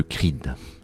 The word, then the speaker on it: CREED: Alors euh, tu fais partie du CREED, mais qu'est-ce que le CREED alors le Creed, qui s'écrit C-R-E-E-D, donc c'est Creed en anglais, ah.